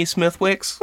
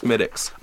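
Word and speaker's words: Smithwick's: Do you have any Smithwicks? – come on. – Yes, it's spelled Smithwicks, but Mike, you host a podcast about beer. You should know that this is pronounced Smittix.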